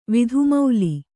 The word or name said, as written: ವಿಧುಮೌಲಿ